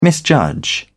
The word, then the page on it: misjudge: British English